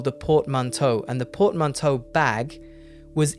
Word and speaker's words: portmanteau: I'm saying it, this is the English pronunciation, portmanteau.